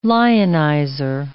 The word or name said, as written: lionizer